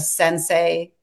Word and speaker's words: sensei: And so, and then also, you can correct my pronunciation, a sensei yes.